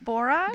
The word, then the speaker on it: borage: We're going by a whole bed of borage. How do you pronounce it? – Borage? – Borage, yeah.